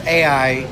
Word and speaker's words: Ajax: It's pronounced AI, just like we would pronounce the initials for artificial intelligence. But it's spelled Ajax, like the the cleaner, the household cleaner.